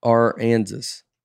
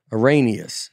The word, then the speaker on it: Aransas: It's like Arkansas, but no K. Aransas. – Okay. – Yeah. I don't ever know how to say it. Aranius.